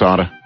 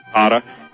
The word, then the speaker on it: Atta: You know, I mean, you know, the interesting thing is Mohammed Atta, or Atta, I don't know if I'm pronouncing his name. I think it's Atta. – Atta.